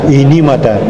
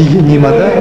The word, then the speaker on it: ινήματα: Ινήματα. – Ινήματα. – Ναι. – Όχι γεννήματα, ινήματα.